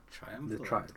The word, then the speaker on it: triumvirate: Triumvirate. – Triumvirate. Let me just. – Triumvirate.